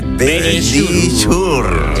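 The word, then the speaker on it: Verisure: Dilo tú, que pronuncia mejor. – Sí, venga, dilo. – Nuestros amigos de Benidichur. – Ahí lo habéis dicho divinamente.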